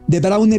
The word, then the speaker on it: De Bruyne: Porque en el común de los países que tienen una generación con esos nombres, De Bruyne, bien pronunciado como lo hace Dani, que es De Bruyne normalmente en flamenco, en neerlandés, todas las vocales que usted no vea son las que se pronuncian. Son idiomas muy opuestos a cómo se escriben, a diferencia del español, por ejemplo, ¿no?